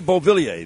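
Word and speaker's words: Beauvillier: Luna kept it away from Bevilier. Now Bailey over for the Islanders. Now off a giveaway, score! Anthony Bevilier sends the Islanders down to Tampa for Game 7 on Friday night. – Anthony Bevilier, that's how you say it.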